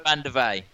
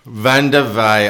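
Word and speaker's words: Vandeweghe: Go on, say that surname for me, Coco. – Coco, what is it? – Coco Van der Vey. – Van der Vey. I didn't want to show myself out and pronounce it totally incorrectly.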